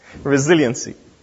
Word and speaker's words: resiliency: How should I pronounce that? My wife is like, you didn't pronounce that properly. You didn't put the accent on the right syllable. Resiliency.